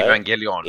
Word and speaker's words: Evangelion: How do you say Evangelion. – It? Evangelion. Now I've, of course, heard of this.